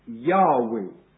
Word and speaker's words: Yahweh: In the Hebrew it appears as J-H-W-H. And the proper pronunciation is probably Yahweh. Yahweh. In fact, the pronunciation Jehovah did not occur until the year 1518, when it occurred in a Roman Catholic book, just a year after Luther had nailed his theses in Wittenberg.